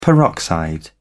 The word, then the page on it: peroxide: British English